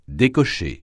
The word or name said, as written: décocher